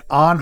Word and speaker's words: On: This one is a Swiss brand and it's called On. Just O-N. And it's pronounced On.